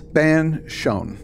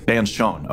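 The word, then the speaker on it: Bean Fionn: Tell me if I'm pronouncing Bian Fion fairies? – Banshon. – Banshon, okay.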